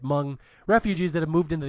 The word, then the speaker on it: Hmong: No, it's pronounced Hmong. – Please. I worked all day to make sure I got that pronunciation correct. I believe it's Hmong, right? The H is silent.